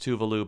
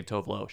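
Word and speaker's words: Tove Lo: I mean, from her, like, introduction to, like, international audiences in 2014, she would say, hey, everyone, it's pronounced Tuvalu, but I don't care if you say Tovlo.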